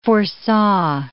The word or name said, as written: foresaw